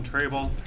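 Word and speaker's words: Trejbal: My name is Christian Trable, chair of the Overlook Neighborhood Association.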